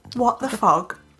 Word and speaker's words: What the FOD: I said it right. – You did say it right, because I say what the pod podcast most of the time, which was a bit strange because we're starting this new little venture and I can't even say the right title.